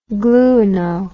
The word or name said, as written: gluino